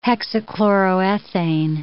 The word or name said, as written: hexachloroethane